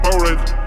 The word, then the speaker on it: Powerade: Engelsk. Ja, Powerade, Powerade. Det er sånn det uttales.